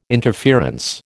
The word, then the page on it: interference: American English